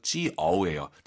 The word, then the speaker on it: 就: 아이유 사이에 오 넣어서 지어예요. 자, 이 지어 발음 들어볼게요, 한번. – 지어. – 그죠? 이보세요, 여러분. 지우, 지우 아니잖아요.